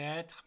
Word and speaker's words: être: Je vais être malade. And actually, you don't even have to pronounce the RE at the end of être in that case because it's followed by a consonant.